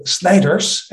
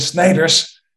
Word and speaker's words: Schneiders: Rather close, but you can imagine it's Christoffel Schneiders, and Schneiders, that is rather hard to pronounce for even the Germans, also for the English, because it's really a Dutch name, Schneiders.